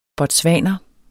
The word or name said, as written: botswaner